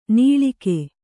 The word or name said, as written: ನಾಳಿಗೆ